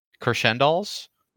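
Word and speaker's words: Crescendolls: Yeah, there was the part near the end, near the middle, where it's, like, the award ceremony for the band, the Crescendals. – I remember that. – Yeah, they're in the audience. That's how you say it? Crescendals? – Yeah. – I mean, it's, like, crescendo and doll.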